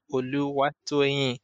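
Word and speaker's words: Oluwatoyin: But for some that have interested pronouncing the full version, Oluwa Toyin, and this Oluwa Toyin, Oluwa Toyin, and I'm like, Oluwa Toyin. Try to break it into syllables for them to be able to call it properly.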